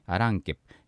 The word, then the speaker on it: アランケプ: アランケプ、最後 P がついてますね。これはものとかっていう意味なんですけど、この P だけではまあ発音できないんですよね。ランケ、アランケについて初めてこう P が発音できると、アランケプ、アランケプ、最後はですね口を閉じて終わるような音になります。